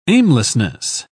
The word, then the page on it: aimlessness: British English